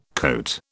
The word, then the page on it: coat: British English